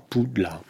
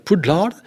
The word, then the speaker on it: Poudlard: mais ça se prononce comment, Pou de lard, parce qu'ils pensaient que c'était un mot anglais. Moi, j'y avais pas pensé à la prononciation Poudlard,